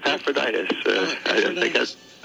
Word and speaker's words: Epaphroditus: Is this Epaphroditus? Epaphroditus? Is that right? – Yeah, that's pretty good. Epaphroditus. I think you did fine.